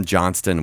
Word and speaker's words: Johnston: I'm Johnston with a T. And so obviously everyone just calls it, like your name, Johnson.